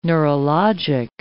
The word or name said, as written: neurologic